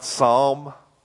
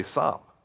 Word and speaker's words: Psalm: The other thing you might want to know since I'm on it is it's not Psalm. The L is silent. You don't say Psalm. You say Psalm. Like S, well, not S-A-M. That would be Sam, but you understand. Okay? Like bomb, like calm.